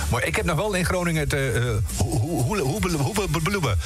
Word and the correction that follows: Hullabaloe: Ik ken dat nog goed uitspreken. Hullabaloe.